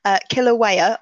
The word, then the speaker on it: Kilauea: Kilauea. I hope I'm pronouncing that correctly. It's spelled K-I-L-A-U-E-A. So Kilauea, I'm sure it is.